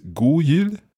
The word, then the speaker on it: god jul: Is it god jul? – God jul. – So you pronounce the D, god jul. – You're meant to pronounce the D, but it doesn't always happen.